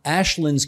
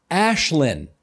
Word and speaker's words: Aislinn: Her name is pronounced Ashlyn. It's Gaelic. I know many people didn't know how to pronounce it on election day either. They just needed to know where she was on the very long ballot. For future reference, remember, it's Ashlyn."